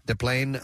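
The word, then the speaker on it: Des Plaines: DePlaine. – 20 miles. Is that how you pronounce it? – Yeah. – DePlaine, Illinois? – Yes, my friend.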